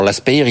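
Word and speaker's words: Laspeyres: Alors les deux mathématiciens s'appellent respectivement l'aspeyre. Alors l'Aspeyer, il insiste beaucoup sur le fait qu'il s'appelle l'Aspeyer, même si en allemand on le prononce pas comme ça.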